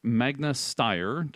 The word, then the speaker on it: Magna Steyr: Also, on Land Rover JLR News, they are partnering with Magna Steyr. Did I say that correctly? – I believe you did.